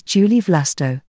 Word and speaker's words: Julie Vlasto: The random article for Tuesday, the 29th of October 2024 is Julie Vlasto. Penelope Julie Didi Vlasto Saperi, French pronunciation, Julie Vlast, the 8th of August 1903, the 2nd of March 1985, was a female tennis player from France.